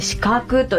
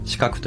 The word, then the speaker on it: 鹿の角: はい、オンラインショップで販売されている家具屋さんということですけれども、鹿の角と書いて四角と読むお名前だそうなんですけれども、こちらの由来、意味っていうのはどういったところにありますか。 – はい、ブランド名は漢字で鹿の角と書いて四角と読むのですが、